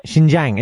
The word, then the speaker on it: Xinjiang: Xinjiang? Is that a place? – That's one place. Xinjiang, is that how you pronounce it? – Yeah, that's the furthest western province in China.